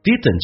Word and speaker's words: didn't: 那么这句话相对比较难一点，有没有一个家长曾经跟你这么去说过？didn't 这个单词，当 d 跟 n 在一起，速度快的时候，经常 d 那个是不发音的。